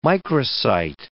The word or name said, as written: microcyte